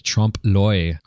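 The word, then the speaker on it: Trompe-l'œil: That song was by Space Phase, and it was called Trompe L'Oeil.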